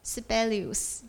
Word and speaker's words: Sibelius: 我不知道芬兰文怎么念，但英文就是 Sibelius，就是这样。